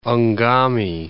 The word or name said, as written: Ngami, Lake